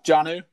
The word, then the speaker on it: Jonnu: Jonnu Smith, is that how you pronounce it? Jonnu? – Jonnu, yeah.